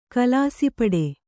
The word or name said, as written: ಕಲಾಸಿಪಡೆ